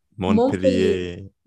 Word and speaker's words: Montpellier: Montpellier.